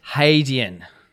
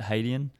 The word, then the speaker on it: Hadean: If you look it up online, that's how it sort of identifies to pronounce it, Hadean. – Yeah, so hay, not had. Had. Hadean.